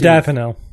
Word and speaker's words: modafinil: Modafinil.